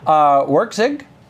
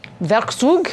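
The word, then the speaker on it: Werkzeug: Out, yeah, it turns out Flask is a micro framework for Python based on WorkZig. – Werkzug.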